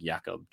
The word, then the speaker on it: Jakob: And then I always say his first name wrong because it's not Jacob Johnson. It's like Jakob Johnson, our fullback. – Jakob. – So he's from Germany.